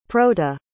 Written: proto

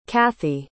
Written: cathy

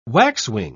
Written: waxwing

